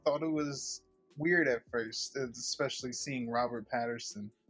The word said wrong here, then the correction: Pattinson: right? That's how you say his name? – Pattinson.